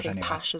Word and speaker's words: Pasch: John was first married to Lucinda Pash. I don't know if I'm pronouncing the surname right. – Pash as well, yeah. P-A-S-C-H.